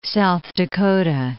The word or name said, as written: South Dakota